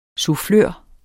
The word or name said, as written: sufflør